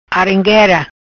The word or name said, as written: Arenguera